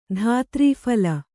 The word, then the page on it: ಧಾತ್ರೀಫಲ: kannada